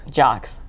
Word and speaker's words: Jacques: Heather, how do you actually pronounce the last name? Is it Jacques? – Jacques. – Jacques. I like Jacques, but I'll go Jacques. Like an athlete.